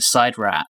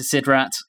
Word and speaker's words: SIDRAT: That ending, as the Doctor, Jamie, and Carstairs are in the side rat. – I hate that pronunciation. – It's how it's pronounced, apparently. I got it wrong last episode.